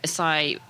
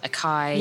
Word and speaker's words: açaí: So açaí, people might have heard mispronounced in the UK as acai,